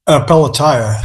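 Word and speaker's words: Pelatiah: Did I say that right? – Yeah, Pelletiah.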